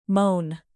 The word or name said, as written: moan